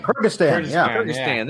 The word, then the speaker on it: Kyrgyzstan: Kyrgyzstan.